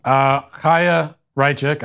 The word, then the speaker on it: Chaya Raichik: Chaya Raichik.